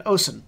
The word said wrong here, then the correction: Ocean: Like, totally mangled pronunciation, but sure, that's good too. – Yeah, that's as far as I can pronounce it. – Yeah, it's Kieran and Ocean.